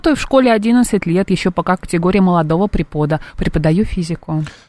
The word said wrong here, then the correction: препода: Препода, наверное, все-таки препода. – Я не знаю, почему я прочитала препода. – Препода, препода. Это ударение современных детей.